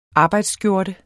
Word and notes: arbejdsskjorte: ˈɑːbɑjds-

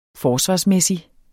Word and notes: forsvarsmæssig: ˈfɒːsvɑsˌmεˀsi; -ˌmεsi

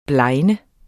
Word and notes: blegne: [ ˈblɑjnə ]

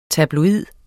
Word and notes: tabloid: [ tabloˈiðˀ ]; [ tɑbloˈiðˀ ]

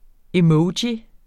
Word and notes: emoji: eˈmʌwdji; ˈεmʌwˌdji

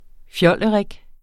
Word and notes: fjollerik: ˈfjʌlˀəˌʁεg; ˈfjʌləˌʁεg

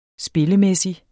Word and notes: spillemæssig: -ˌmεsi; -ˌmεˀsi

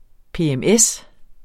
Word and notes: PMS: [ peεmˈεs ]; [ ˈpeˀˈεmˈεs ]

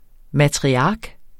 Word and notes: matriark: matʁiˈɑːg

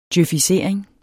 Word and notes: djøfisering: djøfiˈseˀɐ̯eŋ; djœfiˈ-

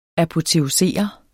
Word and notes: apoteosere: apoteoˈseˀʌ; ɑpo-

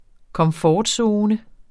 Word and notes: komfortzone: kʌmˈfɒːdˌsoːnə

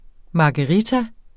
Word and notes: margarita: mɑgəˈʁita; mɑgɑ-

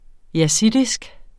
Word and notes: yazidisk: [ jaˈsidisg ]; [ jaˈsiˀdisg ]

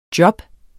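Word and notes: job: Danish

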